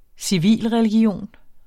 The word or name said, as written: civilreligion